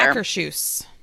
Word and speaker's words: Akershus: I want to say Ocker House or Our House. – Or, Akershus.